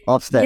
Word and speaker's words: Ofsted: Ofsted.